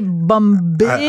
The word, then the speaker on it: Mumbai: toi, tu prononces Mumbai. Oui. Parce que c'est. Avant, ça s'appelait Bombay. – avant, ça s'appelait Bombay. Maintenant, les gens prononcent Mumbai. Toi, tu prononces comme entre les deux.